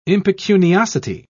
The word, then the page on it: impecuniosity: British English